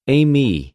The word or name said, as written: εἰμι